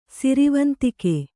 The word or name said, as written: ಸಿರಿವಂತಿಕೆ